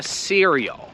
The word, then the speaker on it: cereal: I to się pisze przez C, się wymawia cereal.